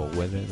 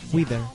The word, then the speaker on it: Wither: O Weather, o no se pronuncia. Wither.